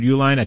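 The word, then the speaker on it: Uihlein: The Restore Sanity PAC is funded by Wisconsin billionaire Richard, is it Uline, I believe is the pronunciation, Richard Uline, a top donor to President Trump.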